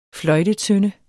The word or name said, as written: fløjtetønde